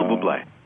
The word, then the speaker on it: Bublé: I mean, it's the instantly recognizable, or Mr. Bublé. Is that how that's pronounced? The fellow from Michael Bublé.